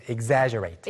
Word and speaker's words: exaggerate: Exaggerate. – 발음 주의해 주세요. Exaggerate.